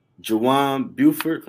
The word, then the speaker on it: Juwan Buford: We have the founder of the JSB Business Solutions Group, Juwan Buford.